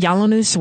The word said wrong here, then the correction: Yiannopoulos: whatever. What's his name, that guy's? – Yiannopoulos, yeah.